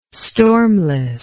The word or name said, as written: stormless